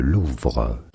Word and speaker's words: Louvre: We're both wrong. – Oh, come on. – Known in France as Le Louvre.